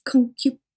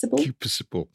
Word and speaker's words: concupiscible: Do any of you know how to say it? Concupiscible? – Concupiscent. – It's concupiscible. – Concupiscible.